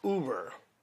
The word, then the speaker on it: Uber: I know it's not Uber, it's Uber.